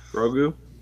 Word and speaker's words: Grogu: Grogu. – Grogu. Child? Is my voice still funny? – Oh yeah. – hold on. – It is. But work with it. Grogu.